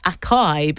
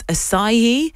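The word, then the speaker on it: açaí: Segue, the Brazilian fruit we thought was acai, but it's acai.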